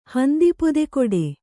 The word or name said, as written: ಹಂದಿಪೊದೆಕೊಡೆ